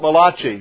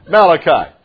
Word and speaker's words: Malachi: Some people pronounce it Malachi, but that was an Italian band leader. And so this is Malachi.